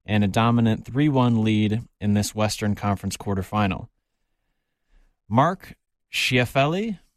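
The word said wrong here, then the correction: Scheifele: excuse me if I pronounced that Shifley. – Word. Mark Shifley. – There you go.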